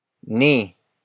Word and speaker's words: ni: Word for word, it's kasing takaw ni Robert si Paolo.